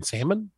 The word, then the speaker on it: salmon: But I found out that my buddy, who was getting married, hates when people pronounce the L in salmon.